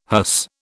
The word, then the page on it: hearse: British English